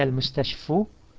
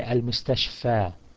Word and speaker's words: المستشفى: You don't say المستشفو, it's actually a long A, and you say المستشفى.